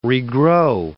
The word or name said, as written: regrow